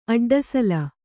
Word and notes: ಅಡ್ಡಸಲ: aḍḍasala

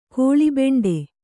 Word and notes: ಕಲ್ಲುಬಂಡೆ: kallubaṇḍe

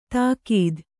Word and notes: ತಾಕೀದ್: tākīd

